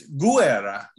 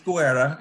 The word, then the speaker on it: Guerra: And in Italy, it's Guerra. Each vowel gets pronounced in Italian, whereas blended in Spanish.